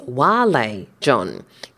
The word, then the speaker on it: vale: Actually, the correct pronunciation is Vale John, because it's from the Latin word vale. And in Latin, V's are pronounced W, like W's.